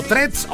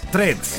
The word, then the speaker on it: Threads: O threads o tweets. Sin la A, threads. Treads.